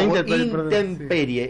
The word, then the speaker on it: intemperie: Interperie. Ah, intemperie.